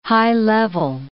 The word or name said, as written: high-level